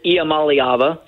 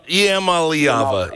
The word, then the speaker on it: Iamaleava: And is that how you say it, by the way? – I believe it is Iamaleava. – Iamaleava. Iamaleava. Okay.